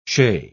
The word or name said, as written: shay